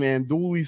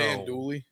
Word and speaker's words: andouille: But I like a nice bowl of gumbo with some andouille sausage in there, dude, bro. – Stop. – What is it? How you say it? – You just say andouille.